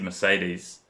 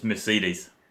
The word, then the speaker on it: Mercedes: Sorry, Chris, I've just got to correct your pronunciation there. You said Mercedes. – Yeah. – Well, according to Alf, it's Mercedes.